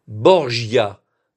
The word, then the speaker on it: Borgia: Berja, c'est la prononciation espagnole. Borgia, vous connaissez mieux, c'est la prononciation française et italienne.